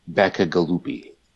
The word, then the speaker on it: Bacigalupi: Yes, it's a Paolo Bacigalupi.